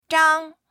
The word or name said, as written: zhang1